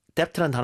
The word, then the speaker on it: depth: 이게 이제 depth 발음을 해줬다가 th로 가야 되거든요. – 네. – depth. – 맞아요. depth. – depth. 그렇죠. 그래서 입술이 붙었다가 떨어지면서 혀가 윗니 아랫니 사이에 이제 빠져나오는 그 형태로 depth.